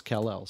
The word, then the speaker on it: Callel: And what's weird about that, too, is that now it's spelled C-A-L-L-E-L, but it's pronounced Kal-El.